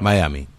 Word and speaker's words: Miami: Bueno, en inglés es Miami. En español, sería Miami, porque en español se lee, como se pronuncia como se lee.